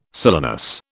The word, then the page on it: silenus: British English